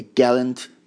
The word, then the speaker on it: gallant: و سه تا تلفظ مختلف هست. یه دونه gallant، gallant، یه gallant، یه gallant، gallant. این سه تا در واقع تلفظ برای این واژه هستش، ولی بیشتر gallant هستش که خیلی بیشتر از همه استفاده میشه، یعنی استرس روی جی باشه.